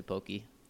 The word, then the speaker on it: poke: So is that the right way to pronounce it, poke? – Yeah. – I always say pokey.